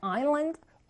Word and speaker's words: island: 아니, 왜 소리가 안 나는데 여기다가 이렇게 s를 썼을까요? 선생님, 저 헷갈리게 만들려고 하는 걸까요? 영어는 좀 그런 게 있어요, 여러분. 그래서 어떤 단어는, 소리를 내지 않기 때문에, 여기 있는 묵음이라고 하는 거거든요. 소리가 나지 않는다 해서 소리 나지 않는 것들이 써져 있으니까, 여러분들이 외울 때, island, s 빼먹고 쓰면 절대 안 됩니다.